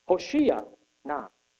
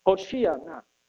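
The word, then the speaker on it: hoshia na: And here, the Hebrew word "save us" is it reads Hoshea na, and that is Hebrew pronunciation, Hoshea na.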